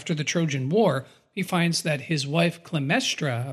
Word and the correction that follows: Clytemnestra: I might be saying that wrong as well. – Is that Clytemnestra? – Yeah, I'm not good at pronouncing these names.